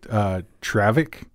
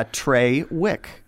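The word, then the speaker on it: Traywick: You know what's funny is that it might not have actually been pronounced Trawick. It might have been pronounced Travik. It might have been like a German. – Well, Travik. – It's T-R-A-Y-W-I-C-K. Yeah, Trawick.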